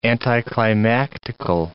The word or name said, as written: anticlimactical